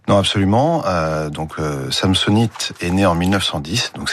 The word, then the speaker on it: Samsonite: Faut le prononcer à la française, hein, Samsonite.